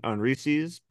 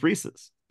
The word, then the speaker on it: Reese's: I think I've probably overdone it in my life on Reese's, but don't say it like that. People get mad. – No, it's my friend Reese and it's Reese's. – Quick, how do you say it? – It's Reese and it's Reese's, but I don't care. – It's Reese's. – It's like, Reese's? – It's Reese's as in it belongs to Reese.